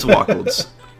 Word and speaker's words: Wacholz: It's Wacholz. – Okay, sweet. – Yeah. Like waffles with two Ks instead of Fs.